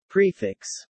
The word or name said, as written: prefix